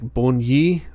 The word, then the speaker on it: Borgne: And it's pronounced like Borgne. Borgne. So there's like a hard E at the end, but it's like a Borgne.